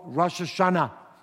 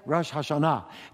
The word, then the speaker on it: Rosh Hashanah: First, the holiday is called Rosh Hashanah, or as others would say, Rosh Hashanah. Depends where you put your syllable.